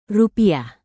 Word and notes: rupiah: indonesian

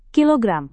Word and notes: kilogram: Indonesian

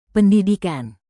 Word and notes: pendidikan: indonesian

